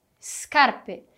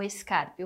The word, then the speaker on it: scarpe: Perceba que o S no início da palavra é mudo, tem um som mudo. Não vou falar como se tivesse um E ou um I ali, como scarpe ou escarpe. O correto é scarpe.